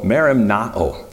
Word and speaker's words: merimnao: The Greek word here is used, and it's called merimnao. That's how you pronounce that.